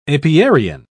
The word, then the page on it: apiarian: British English